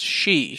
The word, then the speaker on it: sidhe: He's also he's an elf-like race that exists in Warplords, which is, I'll say this again in the future, it looks like it's pronounced side, but it's not. It's S-H-I-D, wait, no, S-I-D-H-E, and that's pronounced she. – It's pronounced she.